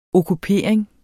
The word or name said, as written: okkupering